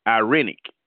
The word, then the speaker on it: irenic: Irenic. I-R-E-N-I-C is pronounced irenic.